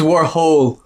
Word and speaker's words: Warhol: So he was trying to say it because I'm pretty sure it's Warhol. – But he was like, no, it's Warhol. Like holes.